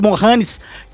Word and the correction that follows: Mahomes: que o oh, Mahomes, desculpa, que Mahomes.